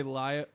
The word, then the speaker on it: Lao: Lao? – I think Los? – You should say Lao. – I is think that it's racist? – Laotian would be the American pronunciation.